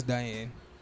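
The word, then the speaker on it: Diahann: Duh, when you pronounce it, Diane. – Yeah, it doesn't like Diane. – No, it is Diane. – Oh, it's sure? – Yeah. – Oh. – You don't pronounce the H.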